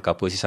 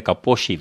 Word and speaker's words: Kaposi: deur 'n Hongaar, dink ek genaamd Kaposi, so die regte uitspraak sal seker Kaposi wees hy het agtergekom dat in die mediterrane mans, in ou mediterrane mans dit voorkom.